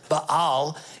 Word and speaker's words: Baal: The first one was Baal, or the Hebrews would actually pronounce it Baal. If you want to sound smart with somebody, you don't say Baal, you say Baal.